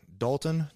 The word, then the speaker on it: Dalton: Dalton, D-A-L-T-O-N, Liebschwager, L-I-E-B-S-C-H-W-A-G-E-R.